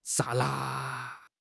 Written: 刹那

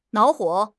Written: 恼火

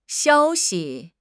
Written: xiao1 xi5

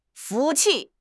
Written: 服气

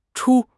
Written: chu1

